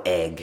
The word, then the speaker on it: Eigg: And it's spelled E-I-G-G, right? It's slightly, I suppose the American sort of audience might not get that. I didn't get the front. Yeah, I said Eeg the first time I saw it, but it's Egg.